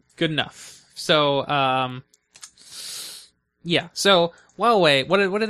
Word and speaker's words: Huawei: I think that's pronounced Huawei. – Huawei. – Yeah. I like Huawei better, personally, but apparently... – Is it actually how... – Apparently, that is what okay. – official sources pronounce it as.